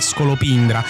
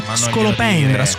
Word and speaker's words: scolopendra: Io ricorderei a Matteo Strano che non si dice scolopindra alla milanese, ma si dice scolopendra con la E aperta.